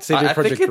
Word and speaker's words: CD Projekt: CD Projekt Red and CD Projekt Group. – It's CD Projekt. CD Projekt. You got to say it properly.